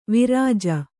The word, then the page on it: ವಿರಜ: Kannada